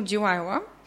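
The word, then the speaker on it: Delilah: дори в заглавието на Том Джонс и песента му Дилайла, което е английското произношение на Далила.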